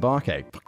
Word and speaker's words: Obake: In 1994, historian Glenn Grant published Obake, Ghost Stories in Hawaii, pronounced Obake. Nailed it.